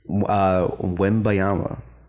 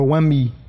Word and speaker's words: Wembanyama: Wembanyama. – Wembanyama or Wemby.